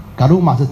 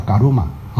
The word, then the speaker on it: Karma: 两个音节，它是念伽鲁玛，伽鲁玛啊，它是用我们用的罗马拼音叫伽鲁玛，如果用英语的拼音叫做卡玛，是这样啊，卡玛啊，所以你说听到人家讲伽鲁玛，那是正确的音呢，你不要说认错啦，伽鲁玛是正确的。只是现在人一般都读英文都是用英文的发音啊，啊，Karma 是用我们罗马拼音念出的梵音，叫嘎鲁玛，哈、哦，就这样。